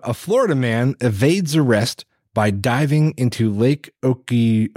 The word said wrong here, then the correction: Okeechobee: Okeechobee, Oke, wait, how do you say it? Okeechobee, yeah.